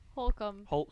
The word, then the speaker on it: Holcomb: Holcomb. – Spell it for us. – H-O-L-C-O-M Motors, and it was just like an automotive company I made in my dream, so I wrote down the name of it.